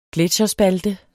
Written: gletsjerspalte

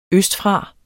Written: østfra

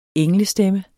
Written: englestemme

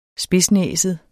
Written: spidsnæset

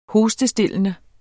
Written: hostestillende

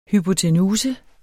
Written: hypotenuse

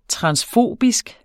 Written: transfobisk

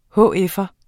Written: hf'er

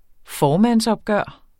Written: formandsopgør

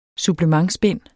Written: SUPPLEMENTBIND

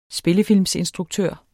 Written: spillefilm

